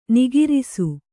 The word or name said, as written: ನಕಾರಿಸು